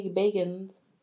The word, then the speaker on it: Bagans: We can be like Zach Bagans, and we can be like... – Zach Bagans? – That's his name. – Zach Bagans? – Mm-hmm. Bagans.